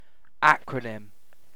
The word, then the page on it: acronym: British English